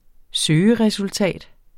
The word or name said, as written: søgeresultat